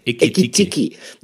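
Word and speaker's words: Ekitike: Habe ich ihn richtig ausgesprochen? Ekitiki? Nee, Moment, nochmal von vorne. Ikitiki.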